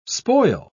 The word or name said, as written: spoil